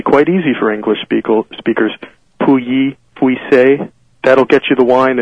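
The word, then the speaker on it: Pouilly-Fuissé: The big one is you'll hear things like Pouilly Fussy or Pouilly. I've heard it a million different ways, all of them wrong. The French pronunciation is Pouilly Fussy, but you don't really have to French it up that much.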